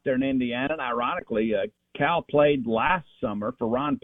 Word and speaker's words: Khal: It's spelled K-H-A-L, but it's pronounced Cal.